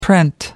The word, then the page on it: print: American English